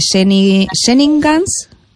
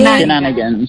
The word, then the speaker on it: Shenanigans: Bueno, invitamos a todos nuestros oyentes a que estén atentos de Nocómbaro, de Jenningans, ¿pronuncié? Shenanigans.